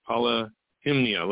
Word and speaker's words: Polyhymnia: And finally, the hard one to pronounce, Polyhymnia. That's the best I can do. Polyhymnia, like it's got the word hymn in it.